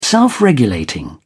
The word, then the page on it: self-regulating: British English